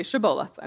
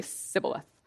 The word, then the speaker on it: Shibboleth: So Jephthah basically tests everyone who's suspected of being from Ephraim by saying, "Say Shibboleth," and they would say Sibboleth. And that just means like a stream or a head of grain. And if they were from Ephraim, they couldn't say it correctly, and it was exposed who they were.